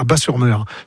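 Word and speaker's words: Batz-sur-Mer: Et surtout, la crêperie s'appelle le Dervin à Bas-sur-Mer, qui font les meilleures crêpes de la domaine. – Oui, Bat, moi, ouais. on prononce Bas, le T ouais, ne se prononce ouais. pas, Bas-sur-Mer.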